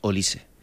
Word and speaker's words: Olise: Es Olise. Indudable, Olise. Es indudable que el galáctico, no por actitud, pero sí por juego, que quiere el Real Madrid, sin ninguna duda es Olise.